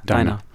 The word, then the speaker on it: Dinah: So this is a song called, I don't know how to say this, D-I-N-A. – Diner. – Dinah. – Dinah's a girl's name.